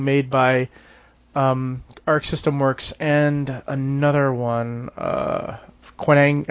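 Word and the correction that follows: Qanba: however you pronounce that name. – Kamba. – Kamba, yeah. And that'll come to the United States, don't worry.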